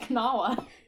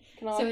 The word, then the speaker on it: Kanawha: And that is that it's pronounced... I can't pronounce it now. It's pronounced Kanawha. – Kanawha County, Kanawha River.